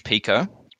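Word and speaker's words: Pekoe: Today we have Orange Pico, and I finally figured out how to pronounce it because I saw it in a movie. It's pronounced Orange Pico,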